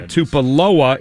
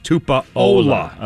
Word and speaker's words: Tupuola: Oh, not Tupuola, it's Tupuola. – Okay. – So I had the U in the wrong spot.